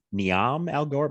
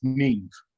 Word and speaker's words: Niamh: I thought it was Niamh Algar, but I think she's Irish. – She's Irish and it's pronounced Neve.